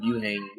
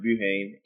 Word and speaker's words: Buhain: Buhane, B-U-H-A-I-N. Different pronunciations just depending on which country. I'm originally from the Philippines, but ever since I moved to America, everyone's been pronouncing it Buhane, so I just ran with it.